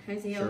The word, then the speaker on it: Hazael: This Hazael, how do you say Hazael. That right? Hazael, sure.